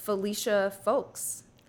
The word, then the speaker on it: Felicia Folkes: And Felicia Folkes.